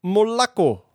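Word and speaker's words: Molakko (молоко): En de melkkoeien in Rusland, het bedrijf daarvan had Molokko in de naam, omdat Molokko niet eenmaal Russisch is voor melk. Wat bleek? We moeten zeggen Molakko. Er werd ons gemeld Molakko. En ja, dat is ons gemaild dat de uitspraak Molacco is.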